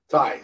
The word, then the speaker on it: Tay: Ty, sorry, Ty Conti. – Ty Conti. Ty. Sorry, said it wrong. – I have such a hard time calling her Ty, if only because I know that's how it's pronounced.